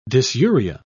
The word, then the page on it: dysuria: British English